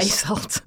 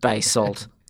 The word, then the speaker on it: basalt: Basalt. – Basalt.